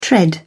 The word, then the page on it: tread: British English